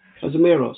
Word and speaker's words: Casemiro: Camaroso. Casemiro, sorry.